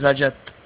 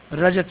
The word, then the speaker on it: Rajat: My name is Rajat. – Najat? – Rajat Srivastav, R-A-J-A-T. – R-A-J-A-T, okay, Rajat.